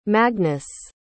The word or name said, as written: magnus